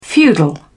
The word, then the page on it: feudal: British English